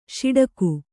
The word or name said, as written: ಷಡಕಿ